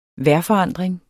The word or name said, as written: vejrforandring